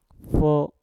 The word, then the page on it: fo: Mpur